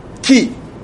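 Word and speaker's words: Ki (כי): by the Hebrew word ki.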